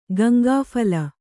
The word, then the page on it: ಗಂಗಾಫಲ: kannada